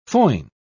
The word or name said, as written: foin